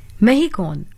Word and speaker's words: MHECON: Mehikon, which is spelled as MHECON 2026 Mehikon,